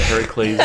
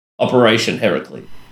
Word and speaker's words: Heracles: Heracles is the project name, like Project Heracles or whatever, like the name that MI6 gave the Operation Heracles. – Okay. – Do we know the story of Heracles and why it's called Heracles? – Well, that's Hercules. – Yeah, yeah, it's the Greek pronunciation of Hercules.